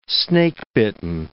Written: snakebitten

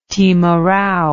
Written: timarau

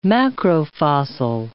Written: macrofossil

